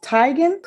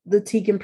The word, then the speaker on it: Teigen: The traditional way to actually say her last name is pronounced Teigen. Chrissy says that she kind of gave up on people pronouncing it that way. And she said she kind of prefers the Teigen pronunciation anyway.